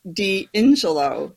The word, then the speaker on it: D'Ingillo: It's pronounced D'Ingillo.